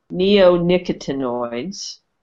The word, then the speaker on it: neonicotinoids: So so the uh, the the word is properly pronounced neonicotinoids.